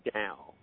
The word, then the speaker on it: Tao: It's pronounced Tao. T-A-O. Tao Te Ching. T-E. T for T, E for elephant. Tao Te Ching, C-H-I-N-G.